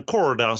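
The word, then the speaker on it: Korda: Vände ner i set över amerikaner och femtesidare i turneringen Sebastian Corda. Eh, eller Sebastian Corda som man kanske ska uttalas nu när han är amerikan.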